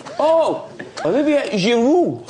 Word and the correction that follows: Giroud: No! No W. Not Giroud.